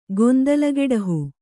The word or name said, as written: ಗೊಂದಲಗೆಡಹು